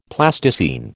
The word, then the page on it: Plasticine: American English